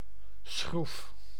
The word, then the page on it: propeller: /pɹəˈpɛl.ə(ɹ)/; English